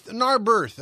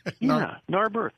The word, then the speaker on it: Narberth: And then in the last few moments, we heard from John of Narberth. Am I pronouncing that right? – Narberth. I think it's like there's an R near the end there. – Narberth.